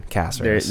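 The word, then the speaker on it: Cáceres: We discussed his proper pronunciation, Caceres. – There's an accent on the A.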